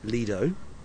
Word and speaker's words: Lido: I can tell you that the correct pronunciation is Lido, because the word comes from Lido di Venezia, a place in Italy.